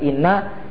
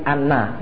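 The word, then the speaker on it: anna (أنّ): Jadi bacanya itu bukan inna tetapi anna.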